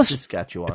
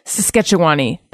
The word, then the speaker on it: Saskatchewan: Well, Saskatchewan. – It's a lot less accent on the first syllable.